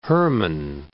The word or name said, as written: Hermon, Mount